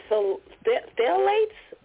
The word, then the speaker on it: phthalates: Am I saying phthalates? P-H-T-H-A-T-E-S. It's P-H-T-H-A-L-A-T-E-S.